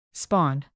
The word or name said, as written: spawn